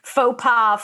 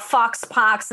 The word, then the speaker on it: faux pas: So, you know, I'm the kind of person that was pronouncing faux pas, fox pox until I was like, I don't know, 25 years old.